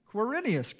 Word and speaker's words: Quirinius: Quirinius, good job, was governor in Syria.